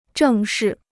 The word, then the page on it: 正是: zhèng shì